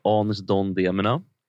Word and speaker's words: Anis Don Demina: Så här, om man inte känner till honom, Anis Don Demina är hur det uttalas. Vi ska lyssna på de första försöken som jag ändå tyckte lät. – Klockan 8 kommer Anis Don Demina.